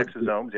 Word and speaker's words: exosomes: Is that how it's pronounced? – Yeah, no, exosomes, yeah.